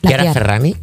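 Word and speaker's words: Chiara Ferragni: Chiara Ferrani, ¿lo he dicho bien? – Ahora sí.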